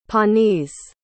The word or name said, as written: Pawnees